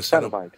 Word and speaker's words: Cenobite: I did not know that the character is, what is it, a Cenobite? Is that how you say it? – Yes, a Cenobite.